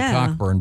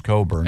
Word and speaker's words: Cockburn: It's spelled kind of that way. Cockburn, but it's pronounced Cockburn.